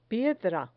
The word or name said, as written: piedra